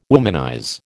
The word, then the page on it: womanize: American English